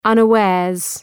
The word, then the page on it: unawares: ,ʌnə’weərz